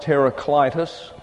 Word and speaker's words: Heraclitus: His name is pronounced Heraclitus.